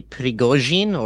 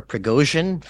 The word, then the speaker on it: Prigozhin: Yes, well, the Wagner Group is led by a man named Yevgeny Prigozhin, or Prigozhin, for if anybody wants to pronounce it the non-Slavic way.